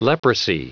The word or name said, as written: leprosy